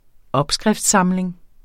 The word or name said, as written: opskrift